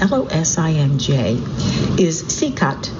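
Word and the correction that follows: Čikat: Chikat. It's nice, he's giving me pronunciations as I go. So the jewel of Losinge is Chikat, C-I-K-A-T,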